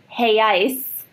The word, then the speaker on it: reais: burglars broke into the Banco Central and made off with 164.7 million reais. – Jeez! – Which is how you apparently pronounce the Brazilian currency.